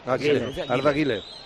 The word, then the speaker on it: Arda Güler: Arda Güler. – Segundo partido. – ¿El qué? – Arda Güler, lo he dicho bien. – Le pronuncian Güler. – Arda Güler.